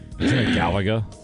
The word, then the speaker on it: Galaga: Text line Galala. Is that my saying that right? – Galaga. – Galaga.